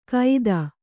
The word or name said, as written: ಕೈತ